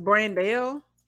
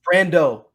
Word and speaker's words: Brando: Then they You say his name is Brandell? – Brando Murphy. – Why not Brando Murphy? – Like Marlon Brando.